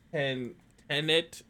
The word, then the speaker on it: Tenet: Is it Tenet? – Yes, forward and backwards.